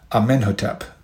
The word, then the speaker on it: Amenhotep: He held the throne for approximately 25 years and passed power on to his son, Amenhotep, and that's spelled A-M-E-N-H-O-T-E-P, Amenhotep. This